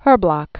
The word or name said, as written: Herblock